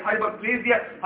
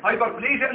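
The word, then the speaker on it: hyperplasia: طبعا بليجيا دي هتعملنا كتير وزي بعض الناس بتنطقها بليزيا ويتكع على تكتب الشوز كده ماشي مش مشكله دوس بالشوز معاك عايز هايبر بليزيا هايبر بليجيا انت في المنصوره اي نطق مقبول.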